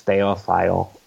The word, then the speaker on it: Thayil: I mean, I've kind of fudged it a little bit. It's Thayil, Thayil. – Okay. – Thayil. – All right. – I probably pronounce it both ways.